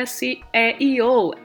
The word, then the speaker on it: SEO: SEO. Tem gente que fala SEO, mas a letrinha é S é IO.